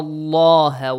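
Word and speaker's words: Allah (الله): Inna allaha wasi'un alim. Do not say inna allaha wasi'un alim. No. The lam of the word Allah will be pronounced heavily.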